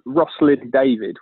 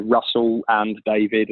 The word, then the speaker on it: Rosslyn David: So we're called Rosslyn David, which people say Russell and David and get the name wrong all the time.